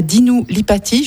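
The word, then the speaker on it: Dinu Lipatti: Un hommage à Dinu Lipati, je ne sais pas si je prononce son nom correctement. – Oui, c'est correct.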